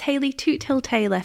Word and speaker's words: Hayley Toothill-Taylor: So it's Hayley Toothill-Taylor for anyone who wants to know how to pronounce it.